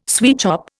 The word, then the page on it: sweatshop: Indian